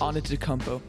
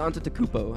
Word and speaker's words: Antetokounmpo: Can anyone pronounce Giannis' last name correctly? – Giannis Antetokounmpo. – It's Antetokounmpo, I think.